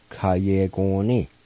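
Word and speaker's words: Kuyegongni: Pronounce like this. Ku ye gong ni. Ku ye gong ni. Ku ye gong ni. And sometimes you might hear somebody pronounce it with a K-A sound like this. Ka ye gong ni. So either way, it's correct.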